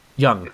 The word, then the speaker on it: Jung: His name is Carl Jung. Young, I think. – Is it J-U-N-G, or how do you? – Yes. – I think that's Young, but who knows? – Yeah, it's.